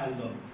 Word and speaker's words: الله: اگر قبل از الله مثبت ا بیاد شما الله باید خفیف تلفظ بکنید با صفت استفال تلفظ بکنید میگه بسم الله اسم یعنی قبل الله چیه ا بسم الله الرحمن الرحیم بسم الله الله الله میشه الله اگه قبلش او باشه چجوری میگی؟ میگه رسول الله رسول الله اصلا میگه رسول الله خنددار میشه اصلا بدت میاد بسم الله خنددار میشه همینه خب بسم الله باید باشه چرا؟